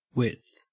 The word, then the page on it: width: Australian English